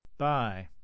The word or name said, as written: buy